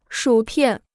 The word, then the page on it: 薯片: shǔ piàn